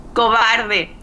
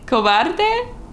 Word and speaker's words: cobarde: Cobarde. – Cobarde? – Yes!